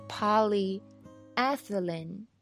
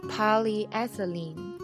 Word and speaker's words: polyethylene: 好，那我们去对照一遍正确的发音，你会发现正确的发音是什么呢？念的是 polyethylene， polyethylene。